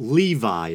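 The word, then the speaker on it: Lévi: And I also want to clarify for those of you who may have seen the first Baphomet documentary that I believe I pronounced the name Eliphas Levi. But upon further research, it appears that Levi is the more proper pronunciation, so that's what I'm going to be going with.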